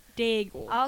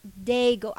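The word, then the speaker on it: Daigle: It's like bagel, but Dagle. – Okay, Dagle.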